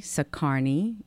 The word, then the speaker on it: Sacharny: Renee, R-E-N-E-E, Sacharny, S-A-C-H-A-R-N-Y.